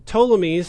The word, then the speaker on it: Ptolemies: Now, if you are writing down notes, Seleucid is spelled S-E-L-E-U-C-I-D, and Ptolemies surprisingly starts with a silent P. So it's P-T-O-L-E-M-I-E-S.